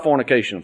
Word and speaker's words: fornication: It is simple. Fornication. Four syllables. I think that four syllables.